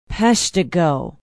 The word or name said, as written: peshtigo